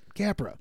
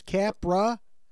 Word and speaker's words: Capra: Five letters, though. Two syllables. Capra. Sounds how it's spelled. Spelled how it sounds.